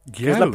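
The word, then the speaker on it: Gyarus (ギャル): Dentro de las culturas urbanas de Japón hay una cosa que se llaman Gyarus. – Gyarus. – Es la pronunciación en japonés de girl.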